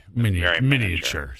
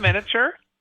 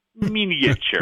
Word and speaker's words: miniature: Compared to you and I, that is, that's like miniature. So there you go. – Not miniature, miniature. – Miniature. That's right. You got to get all the syllables.